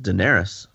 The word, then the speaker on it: Daenerys: Like Daenerys, the reason that she's, by the way, you can rule on this. Is it Daenerys or Daenerys? – It's Daenerys.